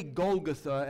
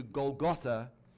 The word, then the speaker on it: Golgotha: I say Golgotha as opposed to Golgotha simply because the emphasis is on the final syllable in the Greek text and on the final vowel.